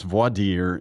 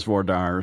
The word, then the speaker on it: voir dire: I mean, they are the same in French, and so it's pronounced voir dire in French. But I've now come around because I'm a Southerner and I'm practicing in Georgia and everybody says voir dire.